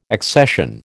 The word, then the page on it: accession: American English